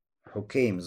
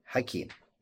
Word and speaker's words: Хукейм: Хукейм с даммой. Мы уже до этого говорили, говорит Ан-Науи, напоминает Ан-Науи, что в двух сахихах, если есть вот такое имя, то оно читается как Хаким. То есть Ха с фатхой. Хаким, не Хукейм. Кроме двух. Вот только два передатчика, у которых Хукейм.